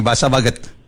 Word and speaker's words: Bahasa Baguet: Ya, betul nggak bacanya nih Bahasa Baget? – Betul ya. Karena saya tadi cari-cari nih, tulisannya kan Bahasa Baguet ini, bacanya apa ya Baguet ini ya? Baget, Baguet atau apa? Sampai saya cari di Translate Google, ternyata Baget bacanya begitu ya, Bu Tasya ya.